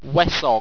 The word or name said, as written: wesong